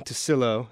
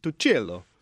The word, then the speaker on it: Tucillo: It's I grew up saying Tusilo. – Tusilo. – Because that's what my parents said. But then I got them to bring back the Italian pronunciation, which two C's in Italian is Tucillo.